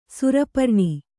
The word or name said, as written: ಸೌರಪುರಾಣ